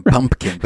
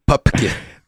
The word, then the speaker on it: Pupkin: I think, yeah, and they always mispronounce his name Pumpkin, but it's Pupkin.